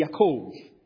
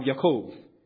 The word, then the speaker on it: Yaakov: And they said, well, I suppose the equivalent name would be Yaakov. That's how you say Jacob. There's no J in Hebrew and the B is pronounced with a V. So you have Yaakov.